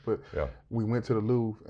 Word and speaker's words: Louvre: The Louvre.